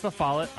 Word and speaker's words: La Follette: Wait, it's La Follette? – It's La Follette. – I like lost wallet. My wallet, La Follette.